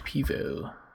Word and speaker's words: pivo: Pivo. – Yeah, that's how they say you're supposed to pronounce it. Actually, C-E-R-N-E-P-I-V-O.